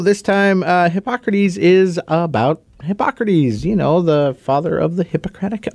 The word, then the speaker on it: Hippocrates: Hippocrates. – I would have pronounced this wrong because I just see hippo. – Hippocrates.